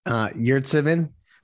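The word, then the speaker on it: Yurtseven: Of course, the other thing was our friend Omer, I guess it's Omer, pronounce it for me. Sorry. – Yurtsimin. – Thank you. Much better than I did.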